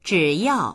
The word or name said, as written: zhi3yao4